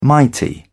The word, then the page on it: mighty: British English